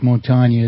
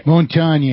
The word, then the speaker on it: Montaigne: Mike Montaigne is how it's pronounced. – Montaigne, okay. – Montaigne, it's French. – Okay. – They always put the accent on the wrong syllable.